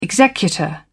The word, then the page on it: executor: British English